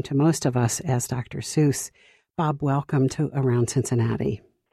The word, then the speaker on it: Dr. Seuss: And then tell us how Theodore Geisel came to be known as Dr. Seuss, if you would, please. – Well, you are pronouncing it correctly, although a lot of people pronounce it Dr. Seuss many times, but you've got it right.